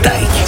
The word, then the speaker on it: Taïk: Moi c'est Taïk. Ça se prononce comme Mike, mais avec un T.